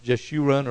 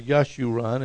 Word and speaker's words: Jeshurun: It's actually a Hebrew word pronounced Jeshurun or Yeshurun, and it's a poetic name for Israel.